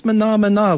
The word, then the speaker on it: Menomena: I think it's pronounced Menomina, like the song from The Muppet Show, but it's spelled M-E-N-O-M-E-N-A.